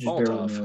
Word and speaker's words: Molotov: Finishing up, Michael pa Palin plays Yakushlev Molotov. I believe that's how it's pronounced. I'm gonna butcher a lot of these, so just bear with me, Molotov.